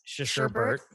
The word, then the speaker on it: sherbet: Sherbet. – But there's no R in there. There's no. It's S H E R B E T.